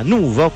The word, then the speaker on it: Nuvo: Complimenti anche a questo personaggio che si chiama Nuvo, con l'accento sulla U mi raccomando, con Sale.